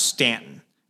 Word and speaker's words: Staunton: It's like Staunton is pronounced Stanton, even though it's Staunton.